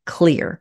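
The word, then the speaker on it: Xlear: Before we go to break, I want to mention one of the show's wonderful sponsors, Clear. I call it X-Lear so my followers know what I am talking about because the X is silent.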